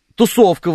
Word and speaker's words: тусовка: Тусовка, Владимир Репин пишет ударение на второй слог, вот к нам прилетели...